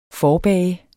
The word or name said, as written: forbage